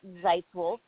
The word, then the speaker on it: Zeitzwolfe: It's at Zeitwolf, so it's spelled ZEITZWOLFE.